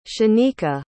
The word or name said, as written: shanika